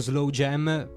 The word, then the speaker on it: SLWJM: tra l'altro noi abbiamo detto Slow Jam, però esatto. Si scrive esatto. Si scrive solo con le consonanti, sì, quindi SLWJM comodo.